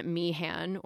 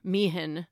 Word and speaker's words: Meehan: And she meets this man, John Meehan, or Meehan. They pronounce his name differently, depending.